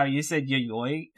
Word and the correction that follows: Yayoi: and Tucker, our pronunciation guy, very explicitly sent us a message saying, do not pronounce it like that. It is yayoi, and I even wrote in bold next to the character's name how to say it.